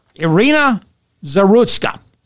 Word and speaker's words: Zarutska: I want you to say her name, and here's how her name is pronounced. Irina Zarutska.